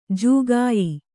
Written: ಜಾಕಾಯಿ

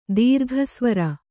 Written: ದೀರ್ಘಸ್ವರ

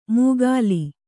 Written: ಮುಗ್ಗಾಲಿ

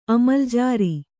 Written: ಅಮಲ್ಜಾರಿ

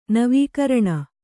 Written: ನವೀಕರಣ

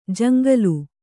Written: ಜಂಗಲಿ

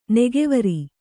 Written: ನೊಗವೊರು

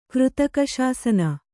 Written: ಕೃತಕಶಾಸನ

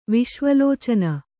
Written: ವಿಶ್ವಲೋಚನ

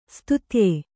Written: ಸ್ತುತ್ಯೆ

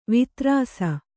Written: ವಿದಾರಿಸು

